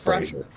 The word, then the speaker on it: Fraser: However you say it, I do remember he just came out and said how it was pronounced, and we've been saying it wrong the whole time. I think it is just Fraser. – Frasier.